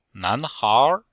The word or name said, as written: nan2hair2